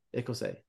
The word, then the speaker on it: Ecosse: You pronounce it? Ecosse? Okay. – Ecosse.